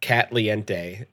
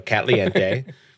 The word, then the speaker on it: Catliente: Made by, and I want to pronounce it as Catliente, like Caliente, yes, but with cat and a few extra E's. So Catliente.